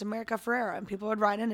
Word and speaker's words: Ferrera: And America Ferrara is leaving. – America Ferrera. – All right. – That's how you say her name.